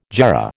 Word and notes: jarrah: American English

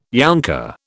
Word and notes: younker: British English

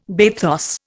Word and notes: bathos: Indian

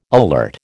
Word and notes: alert: American English

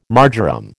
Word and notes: marjoram: American English

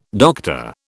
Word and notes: Dr: British English

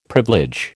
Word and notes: privilege: American English

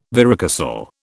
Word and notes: varicocele: British English

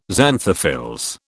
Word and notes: xanthophylls: British English

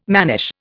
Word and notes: mannish: Indian

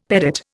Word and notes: pedate: Indian

